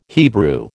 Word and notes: Hebrew: American English

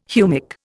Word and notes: humic: Indian

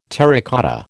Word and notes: terracotta: American English

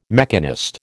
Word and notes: mechanist: American English

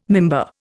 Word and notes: mimbar: Indian

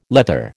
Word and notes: leather: American English

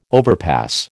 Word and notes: overpass: American English

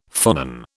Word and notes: phonon: British English

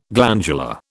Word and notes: glandular: British English

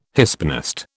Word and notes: Hispanist: British English

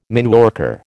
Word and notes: mineworker: American English

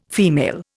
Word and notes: female: Indian

